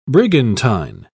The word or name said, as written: brigantine